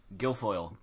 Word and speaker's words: Guilfoyle: They're playing Southern Columbia, and then in one A you have Farrell playing Bishop uh, Gofi? Guilfoyle. Guilfoyle. I knew I spelled that right and just couldn't pronounce it.